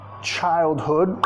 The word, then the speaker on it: childhood: Childhood, acentuación de la palabra, primera sílaba acentuada, segunda, sonido vocálico más reducida.